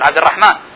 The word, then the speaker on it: عبد الرحمن: هذه الآن أمثلة على أحرف تكتب ولا تنطق، هناك أحرف قد تنطق ولا تكتب مثل عبد الرحمن، هنالك ألف بعد الميم لكن لا نكتبها، لا ننطقها عبد الرحمن، نقول عبد الرحمن.